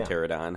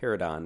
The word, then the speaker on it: Pterodon: Pterodon. – Yeah. – Pterodon Knight. Silent P.